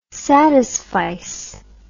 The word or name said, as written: satisfice